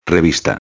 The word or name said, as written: revista